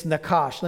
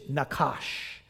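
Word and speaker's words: nakash (נחש): It's nakash. Let me spell it for you. In English, we spell it N-A-C-H-A-S-H, nakash. The word means properly to hiss. You can even say it with a hiss.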